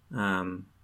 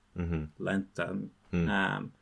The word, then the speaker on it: lentamente: And so, like with adverbs, lentamente, which is the word for slowly, rapidamente, the stress is on just this adverbial ending that doesn't have any meaning besides this is an adverb.